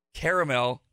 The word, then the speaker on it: caramel: So Carmel is the accepted, proper, most popular version of the pronunciation in America. Caramel is the British pronunciation.